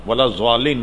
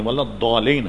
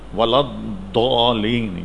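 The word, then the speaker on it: ولا الضالين: اس پورے فتوی میں اعلیٰ حضرت یہ بتایا کہ اس کا تلفظ زال سے ملتا ہوا نہیں، ولا الضالین نہیں ہے، ولا الضالین ہے، ولا الضالین. یہ جو دو ڈاڑے ہیں اس پر زبان کی ایک طرف کو لگا کر نکالا جائے، ولا الضالین.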